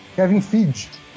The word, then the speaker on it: Kevin Feige: Qual que é a pronúncia, professor? – Kevin Feige. – Tá, obrigado. – Igual Toca o bonde. As Ilhas Fiji.